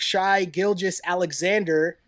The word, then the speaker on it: Shai Gilgeous-Alexander: Number 11, we had the Hornets take Shai Gilgeous-Alexander out of Kentucky, but then trade those rights to the Clippers. – Solid pronunciation, by the way. Solid pronunciation on the name.